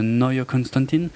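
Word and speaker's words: Neue Constantin: Il les a eu euh, finalement qu'en 86, donc avec euh, sa société de production euh, Neue Konstantin, puisque c'est allemand. Euh, je le prononce comme là-bas.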